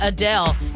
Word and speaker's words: Adele: My name's not Adele. Uh, Adele, it's Adele. Adele or Adele. UH dash Dale.